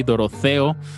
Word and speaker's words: Dorotheo: Comenzamos este mix de ejercicios con, con la banda tapatía Doroteo, que se escribe con H por ahí, Doroteo.